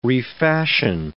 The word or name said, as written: refashion